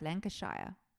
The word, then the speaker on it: Lancashire: Lancashire.